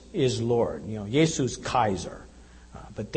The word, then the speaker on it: Kaiser: That's how you would pronounce in Latin. You know, we say Caesar, they would have a hard K sound that would come out as Kaiser.